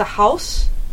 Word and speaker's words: Haus: I mean, have you heard about The House? Mm. It's spelled HAUS.